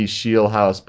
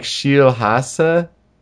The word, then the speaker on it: Scheelhaase: Or at least that's how they say it on TV, Shielhouse. But it's more like Shielhasse. It's like S-C-H-E-E-L-H-A-A-S-E.